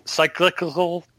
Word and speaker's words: cyclical: It's almost like cyclical? I don't know how to say it. – Cyclical.